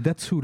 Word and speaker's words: Datsu: Ça se prononce comme ça, Datsu. Et en fait, si tu veux, c'est ce morceau-là.